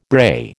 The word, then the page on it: brae: American English